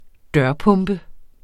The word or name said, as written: dørpumpe